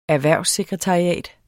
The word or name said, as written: erhvervssekretariat